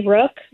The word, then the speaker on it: Rooke: It's at Mary Rook. My last name is spelled R-O-O-K-E.